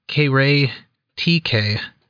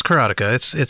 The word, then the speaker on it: Karateka: Kevin Savitz posted on Atari Age that he had gotten some discs from Jordan Mechner for this game, K-Ray TK, something like that. – I loathe you. – No, it's Karateka. I don't care how anyone else pronounces it.